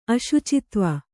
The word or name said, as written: ಅಶುಚಿತ್ವ